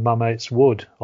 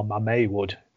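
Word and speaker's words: Mametz Wood: But then also I ended up with my now boss, Richard Osgood, ended up working in Mamet's Wood, or Mamet Wood, should I say. I always pronounce it the English way, Mamet's. Yeah. Apparently it's Mamet. – Okay. – You don't pronounce a Z on the end.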